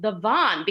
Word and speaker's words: Devon: Yeah, Devon, D-E-V-O-N, is our big street, and we pronounce it the Van